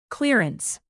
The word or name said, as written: clearance